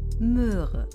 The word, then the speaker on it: Möhre: Auf Deutsch gibt es das Wort Moor und das Wort Möhre. Die hören sich ein bisschen ähnlich an. Aber wenn du mal genau zuhörst, merkst du, dass sie doch ziemlich unterschiedlich ausgesprochen werden. Hör mal. Moor, Möhre. Hörst du das? – Das hört sich für mich gleich an.